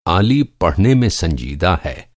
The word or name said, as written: serious